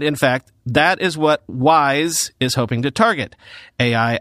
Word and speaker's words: WSE: Cerebras is calling this new monster chip the Wafer Scale Engine, or WSE, although they want you to pronounce that WISE for the purposes of future marketing.